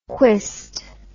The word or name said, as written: quist